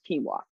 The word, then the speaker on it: quinoa: I heard all about this canola. Apparently it has so much protein, almost like you don't need to eat like meat. And he was like, babes, I love you, but it's pronounced quinoa.